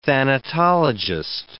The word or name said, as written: thanatologist